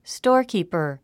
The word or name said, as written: storekeeper